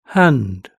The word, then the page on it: hand: British English